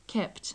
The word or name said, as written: kept